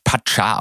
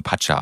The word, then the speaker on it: Pacha: Ähm, Anyway, es stellte sich raus, dass just in dem Jahr ähm, oder im, im Jahr darauf, äh, dass das Pascha, dass man übrigens Pacha ausspricht. Entschuldigung. – Learning Nummer eins ja weiß niemand. Pascha. Ich habe das auch mal Pascha ausgesprochen, aber in, da es in Spanien äh, gegründet wurde, spricht man das dort immer nur Pacha aus.